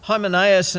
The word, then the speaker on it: Hymenaeus: Hymenaeus and Philetus. I'm not sure, did I pronounce that right, Pastor Bruce? Yeah, oh good.